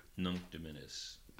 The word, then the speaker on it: Nunc Dimittis: Nunc Dimittis. – Nunc Dimittis.